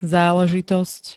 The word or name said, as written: záležitosť